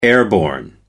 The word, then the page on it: airborne: American English